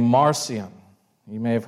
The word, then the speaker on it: Marcion: But Marcion, some people call it, pronounce it Martian, but I just feel like that's weird. So Marcion, and I'll spell it for you if you want to look him up and do some more reading.